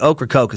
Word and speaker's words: Ocracoke: That doesn't look like it's spelled at all to me, but Ocracoke is what they say.